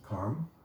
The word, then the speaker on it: karma: There's karma, proper pronunciation is karma.